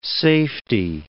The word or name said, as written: safety